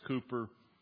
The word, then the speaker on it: Cowper: William Cooper, we call him Cooper here in the United States. In Great Britain, it's William Cowper. Or we call him Cowper, but in England, I'm sorry, he is pronounced Cooper.